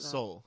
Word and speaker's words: Seoul: How do you say that? – Seoul. – Seoul? Seoul? – Yeah, Seoul.